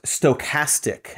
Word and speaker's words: stochastic: It's actually pronounced stochastic, as if you didn't know, you little pumpkin you.